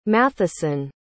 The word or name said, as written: matheson